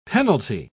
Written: penalty

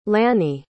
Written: lanny

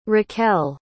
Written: racquel

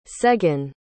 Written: seghen

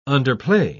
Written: underplay

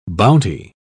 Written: bounty

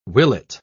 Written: willet

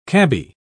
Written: cabbie

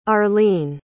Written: arlene

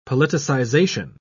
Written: politicization

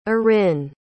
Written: arin

